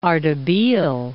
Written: Ardebil

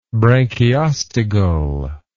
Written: branchiostegal

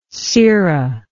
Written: cera

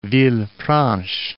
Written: Villefranche

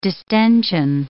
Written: distention

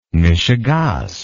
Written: meshugaas

